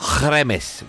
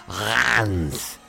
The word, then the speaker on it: Reims: They take on Reims, which is an aftershave. No, it's not pronounced that. It's pronounced Reims, the capital of the Champagne region.